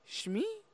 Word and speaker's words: Shmi: He sees his mother, Shmi? Am I saying that right? Shmi Skywalker? – Yeah, that sounds about to me. I think I always say Shimmy, but...